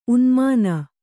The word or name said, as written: ಊನಮಾನ